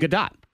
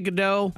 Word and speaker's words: Gadot: How do you say her name? – Gadot. – Gadot. – Gal Gadot. – Yeah, people say it differently. – Because it looks, you want to say Godot.